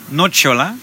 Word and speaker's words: Nocciola: hoe spreek je dat uit? Nocciola. – Nocciola.